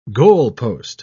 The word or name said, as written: goalpost